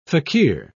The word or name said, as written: fakir